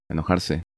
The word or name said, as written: Enojarse